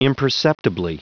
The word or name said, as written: imperceptibly